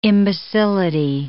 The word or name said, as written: imbecility